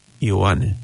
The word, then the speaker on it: Ioane: That means John, of course, but they pronounce it in their language, in Samoan language, Ioane.